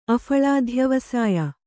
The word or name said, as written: ಅಫಳಾಧ್ಯವಸಾಯ